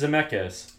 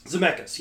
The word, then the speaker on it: Zemeckis: Is that how you say it? I thought it's Zemeckis. – Zemeckis, yes, you're right.